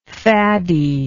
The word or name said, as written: faddiness